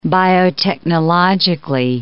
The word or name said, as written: biotechnologically